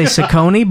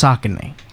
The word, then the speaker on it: Saucony: I used to say Saucony, but I believe it is pronounced Saucony. – Interesting. – Because apparently it is a river in Pennsylvania or something.